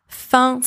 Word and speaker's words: fin: Fin, spelled F-A-I-M, means hunger. Fin, spelled F-I-N, means end. In both words, the letter F is pronounced F. The letters A-I-M together and the letters I-N together in this word are pronounced un. Un, which is a nasal vowel. Parfait. Third set of homophones. Foie, foie, and foie. Foie, spelled F-O-I, means faith.